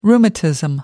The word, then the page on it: rheumatism: American English